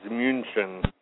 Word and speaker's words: München: Well, even Munich is incorrect. It's München.